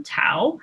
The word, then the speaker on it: Thao: So obviously to most of us, the name Tao is unfamiliar. It's spelled T-H-A-O, but it is properly pronounced with a silent H.